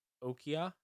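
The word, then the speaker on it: Okoye: She plays Okia. Is that how you pronounce that? – Nailed it.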